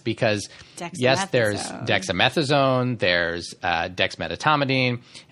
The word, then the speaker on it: dexmedetomidine: One is I see this mispronounced all the time. It's dexmedetomidine, not dexmedetomidine. So people leave out that middle syllable.